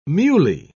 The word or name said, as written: muley